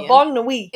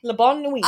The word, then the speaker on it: La Bonne Nuit: So Ronnie gathers the gang at the speakeasy, because I can't pronounce it in French. I know I'm Canadian. – La Bonne Nuit. – Is that it? – La Bonne Nuit.